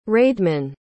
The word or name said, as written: raedeman